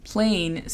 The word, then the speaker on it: plain: Plain significa plano o simple. La pronunciación fonética es P-L-E-I-N, plain. Second, segundo. Delicious, delicioso. La pronunciación fonética es D-E-L-I-S-H-A-S, delicious.